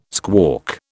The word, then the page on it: squawk: British English